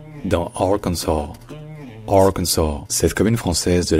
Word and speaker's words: Arkansas: Que le S à la fin, il est prononcé dans Kansas, Kansas, Kansas, alors qu'il n'est pas prononcé dans Arkansas. C'est juste Arkansas, Arkansas.